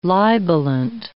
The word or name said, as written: libelant